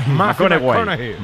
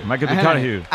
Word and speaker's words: McConaughey: McConaughey. McConaughey.